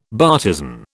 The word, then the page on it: bartizan: British English